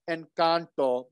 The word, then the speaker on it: Encanto: By the way, I really hate it when people pronounce it with an American accent. It just drives me up. Yeah, it just drives me up the wall. It's like, no, it's Encanto, because, you know, I lived in Peru for 15 years.